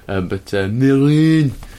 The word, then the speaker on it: Mesrine: Some French people pronounced it with the S. Gérard Depardieu did not. Mesrine.